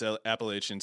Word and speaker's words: Appalachian: So whoever that guy was that got mad at how I pronounced Appalachian State, I'm sorry, there's an I and an A and an N. That's E-N, not in. I can't help it. It's Appalachian State.